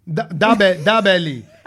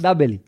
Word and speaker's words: Dabeli: Dabeli. – That's a rapper, isn't it? Dababy. – How's it pronounced? – Dabeli. – Oh, Dabeli. – Dabeli, yeah.